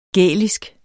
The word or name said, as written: gælisk